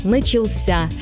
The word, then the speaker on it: начался: А вот в форме прошедшего времени мужского рода ударение уходит в конец – «начался».